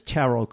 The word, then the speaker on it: tarot: And the one trick for a blind individual I should point out is that when you're using a screen reader, it pronounces it as tarot cards, but it's not spelled like it sounds, is it? – No, it's spelled T-A-R-O-T.